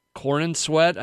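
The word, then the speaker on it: Corenswet: You sent me that, and it's you got the girl from Miss Maisel playing Lois Lane, and some guy named David Cornsweet. – Corn and sweat, I think is how you say it.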